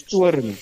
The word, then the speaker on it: Toorn: In Afrikaans, it's called Babylon's Toorn. T-O-O-R-N. – Okay. – And T-O-O-R-N means the wrath in.